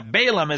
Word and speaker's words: Balaam: Balaam is how it's pronounced in the English, but it's really Bilaam in the Hebrew.